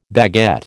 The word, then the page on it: baguette: American English